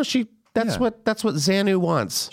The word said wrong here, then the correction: Xenu: The being, Xenu.